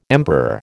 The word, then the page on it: emperor: American English